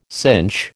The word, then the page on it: cinch: American English